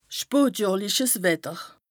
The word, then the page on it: spotjohrliches Wetter: Bas Rhin; Strasbourg